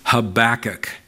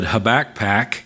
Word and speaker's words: Habakkuk: Habakkuk. There's only really one way to pronounce that. I guess you could try it a couple of different ways. Habakkuk. Some have said Habakpak as another one.